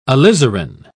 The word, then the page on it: alizarin: British English